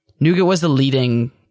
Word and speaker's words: Nougat: Nougat. – Nougat? – Nougat. – As not nougat? – Pronounced in its native tongue. – Now, what is nougat's native tongue? – French.